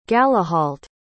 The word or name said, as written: galahault